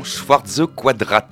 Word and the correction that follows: Schwarzes Quadrat: c'est ça comme ça hein? – Je crois que c'est plus Schwarzes Quadrat. – Ah oui, c'est mieux en effet.